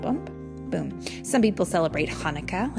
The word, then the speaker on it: Hanukkah: This is how you say Hanukkah correctly. Hanukkah.